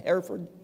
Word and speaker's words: Hereford: And he sent me a note back and said, well, there's a church here near Hereford, H-E-R-E-F-O-R-D. You pronounce that Hereford? Yeah. Hereford.